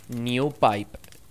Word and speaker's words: NewPipe: En segundo lugar, os recomendamos NewPipe. New, de nuevo, N-E-W, Pipe, se escribe Pipe.